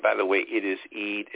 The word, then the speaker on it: Eid: So it is Eid. Imam Muhammad for years tried to, he would spell it different ways. He would put three E's there to make sure we wanted to say Eid, you know, with the long E sound in terms of transliteration so that we would pronounce it correctly.